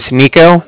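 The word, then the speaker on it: Niko: Like I said, my name's Nico. That's N-I-K-O.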